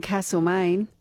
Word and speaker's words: Castlemaine: I'm hearing from you today about the town names people seem to mispronounce, including, of course, Castlemaine. There's no R in Castlemaine.